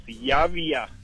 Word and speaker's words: Javier: Help me out, says it Yaya. – It's Yavia.